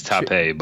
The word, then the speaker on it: Tape: So there was, let's see, Columbia grad transfer, 6'10", 220-pound forward, Patrick Tape, who... – I think it's Tape, but...